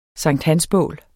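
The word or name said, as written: Sankthansbål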